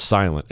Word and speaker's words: SLNT: It's S-L-N-T, but it's pronounced silent, a silent bag.